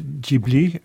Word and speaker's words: Ghibli: Certains disent Ghibli, mais enfin, ça veut dire quoi ? – Oui, c'est en fait, eux, ils prononcent Ghibli, comme disent les Japonais.